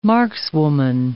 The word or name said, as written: markswoman